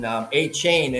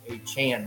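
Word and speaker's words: Achane: You know, they were pronouncing A-Chain, and he come out and say, "No, I want to be A-Chan now."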